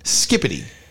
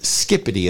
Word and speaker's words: skibidi: Yeah, it's actually pronounced, I know, it's pronounced skippity. Even though it's spelled S-K-I-B-I-D-I, it's pronounced skippity as if there's a P in it.